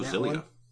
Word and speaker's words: Xillia: I would go Xillia. – Xillia, yeah.